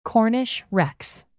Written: cornish rex

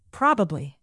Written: probably

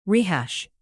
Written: rehash

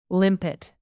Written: limpet

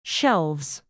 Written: shelves

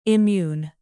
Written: immune

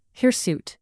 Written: hirsute

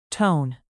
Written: tone

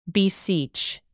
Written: beseech

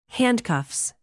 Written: handcuffs

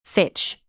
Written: fitch

